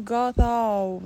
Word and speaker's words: گاتا: بله، الان مثلا همون کلمه گاتا رو ما میگیم گاتا، یعنی زبون فشو که سه نقطه دست می نویسن مثل تیچه انگلیسی تلفظ میشه، زبون میره پشت دندونای بالا و گاتا گفته میشه، گاتا نمیگیم، گاتا یا مزدا، اینا رو به این شکل میگیم.